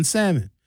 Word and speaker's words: salmon: Don't pronounce the L in salmon.